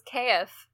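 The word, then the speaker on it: C'aef: Oh, and my fantasy brother's name was Kaif. – Kaif? – Kaif. – How do you spell that? – C apostrophe A-E-F.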